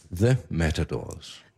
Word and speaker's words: The Matadors: Var det Matadors? Eller hvordan, hvordan siger man det? – Uh, vi, siger, vi sagde The Matadors. – The Matadors. The. – Ja.